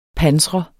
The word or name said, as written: pansret